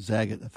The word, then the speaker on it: Zagat: Zagat.